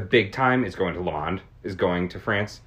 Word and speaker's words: Le Mans: So, in that sense, I have heard of Le Mans. – It's Le Mans, but no D.